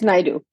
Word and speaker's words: Naidoo: Did I say that right, or is it Naidoo? – It's Naidoo. So Naidoo. Okay, perfect.